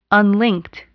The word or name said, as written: unlinked